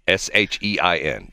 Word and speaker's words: Shein: The first one is called, I don't know if you pronounce it Sheen or Shine. – Yes, I know what that is. – What is Shine? Shein.